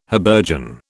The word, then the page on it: habergeon: British English